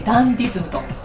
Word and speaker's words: 流儀: タイトルが祖父の流儀で、流儀はこう流儀と書いてダンディズムと読むということです。